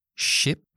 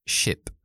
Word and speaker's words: ship: Y una comparación clásica, de hecho hay un libro que se llama así, de pronunciación, es la diferencia entre ship, barco, y sheep, oveja. ¿Notáis la diferencia? ship, ship, la i, i, i, i, ship, ship, sheep, oveja, sheep, i, está la i metido.